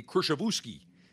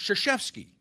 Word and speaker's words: Krzyzewski: phonetically would look, it would be Krzyzewski, but it's Krzyzewski.